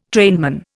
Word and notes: trainman: Indian

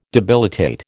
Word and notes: debilitate: American English

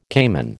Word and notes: cayman: American English

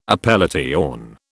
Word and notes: appellation: British English

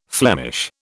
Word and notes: Flemish: British English

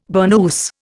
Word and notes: burnous: Indian

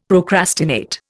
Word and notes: procrastinate: Indian

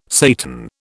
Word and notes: Satan: British English

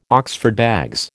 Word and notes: Oxford bags: American English

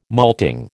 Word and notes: malting: American English

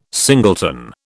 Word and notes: singleton: British English

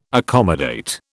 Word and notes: accommodate: British English

